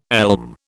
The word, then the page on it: elm: British English